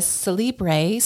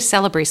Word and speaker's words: Celebree: And she is with Celebree School. Did I say it correctly? – Almost. It's actually Celebree School.